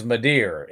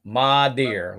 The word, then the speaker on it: Madere: Is it Madrid? – It is Madeer. M-A-D-E-R-E. Madeer. Like a mama deer.